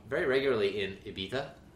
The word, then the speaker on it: Ibiza: So, I pronounced that right. – What was that? – Everybody at home wondering if I pronounced that right. I did. – You know what else there is going on in that word, though? I feel like people say it instead of Ibiza, they say Ibiza.